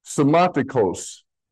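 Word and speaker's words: Somatikos (σωματικός): So these two words, the top one is somatikos. S-O-M, it looks like a U, but it's an M. A-T-I-K-O, long O-S. It looks like a W, it's a long O. Somatikos. The bottom word is a somatikos. S-O-M.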